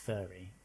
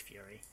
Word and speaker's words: Furi: Although it does look like it can be pronounced furry, but I'm pretty sure it's fury.